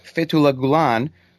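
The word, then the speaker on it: Fethullah Gülen: Fethullah Gulen, who has been living in the United States for roughly the last 10 or 12 years.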